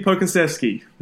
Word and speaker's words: Pokusevsky: But uh, Alexei Pakushevsky. Yeah, Alexei Pokusevsky.